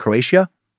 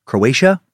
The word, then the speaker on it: Croatia: The two met in Trieste, Italy, a seaport in the northeast part of the country near Slovenia and Croatia. Croatia? I don't know why I added an extra syllable there. Jack wrote about his father.